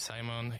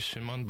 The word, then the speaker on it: Simon: My name's Simon. If someone prefers, like, can call me Shimon, but it's quite a bit harder pronunciation.